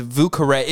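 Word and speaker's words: Vieux Carré: Did you guys know that the cocktail is pronounced Vucare? Oh my God. I thought it was Vucar.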